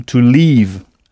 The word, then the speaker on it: leave: Alors, on a aussi appris avec Michel Thomas que « partir » se dit « to leave », « l-e-a-v-e ». Il faut bien prolonger le son « to leave » à ne pas confondre avec « to live » avec un « i » court, qui s'écrit L-I-V-E, qui veut dire vivre, habiter. Bien, alors si en anglais maintenant se dit now, N-O-W, comment dira-t-on je veux partir maintenant? – I want to leave now.